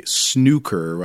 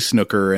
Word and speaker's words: snooker: or as all the characters in this movie say, Snooker. I think that's the British, the common British pronunciation. But we're U.S., so we're going to say Snooker. And if that irritates British listeners, I'm sorry.